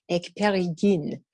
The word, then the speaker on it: Ekperigin: Okay, so if I go to France, for example, they can say the name no problem. Ekperigin. Everywhere else, no one else can seem to say it or spell it.